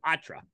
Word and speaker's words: Poitras: How the fuck is Poitras? It's spelled Poitras, but yeah, it's fucking Patra.